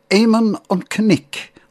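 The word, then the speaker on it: Éamonn an Chnoic: The Irish pronunciation is Éamon an Cnic, or Ned of the Hills.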